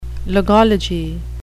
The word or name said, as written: logology